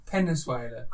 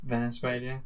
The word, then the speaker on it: Venezuela: Penezuela. – Venezuela.